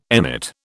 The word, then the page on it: emmet: British English